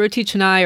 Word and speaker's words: roti canai: is the roti canai. Or how do you pronounce it? – Mm-hmm. Yeah. Yeah. It's roti canai or roti paratha.